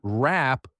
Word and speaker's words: wrap: Notice that the W at the beginning of the word wrap is not pronounced.